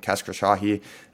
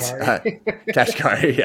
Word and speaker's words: Kashkari: So Bullard said something kind of different than, how do you pronounce his last name? Kashkari. It seems like there's kind of dissent. Kashkari. Yeah, Jesus.